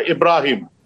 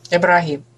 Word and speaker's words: Ибрагим: Но, кстати, пишется по-разному. И в всяких википедиях, и в словарях кто-то пишет Эбрагим, кто-то пишет Ибрагим. Но произносится вот так более мягко – Ибрагим. – Ибрагим.